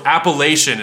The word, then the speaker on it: Appalachian: So Appalachian is the correct pronunciation, right? – Yeah.